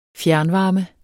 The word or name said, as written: fjernvarme